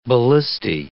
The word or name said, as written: ballistae